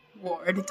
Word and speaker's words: Ward: A-F-I-Y-A. – And last name? – Ward, W-A-R-D.